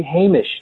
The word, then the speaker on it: Hamish: that we would say Hamish.